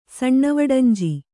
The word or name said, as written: ಸಣ್ಣವಡಂಜಿ